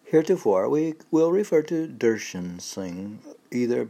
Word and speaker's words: Darshan: Had he been only a poet, Darshan. Remember how the pronunciation was like Darshan? Not Darshan, but Darshan.